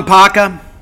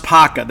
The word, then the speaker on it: Kpaka: Suleiman Paka, it's spelled K-P-A-K-A. But it's pronounced Paka. The K is silent.